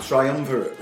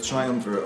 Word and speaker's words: triumvirate: And Simon Mayo stopped and said, what did you just call it? A triumvirate. – Yeah. Right. – And he said, that's not how you pronounce it.